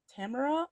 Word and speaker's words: Tamora: Uh, I went to her website to look up background, uh, and apparently she pronounces her name Tamara, like Camera. – Oh, I'm so sorry. – No, Tamara. no, I grew up saying Tamora Pierce, Tamora Pierce, and then someone's like, apparently it's ta-.